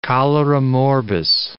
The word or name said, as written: cholera morbus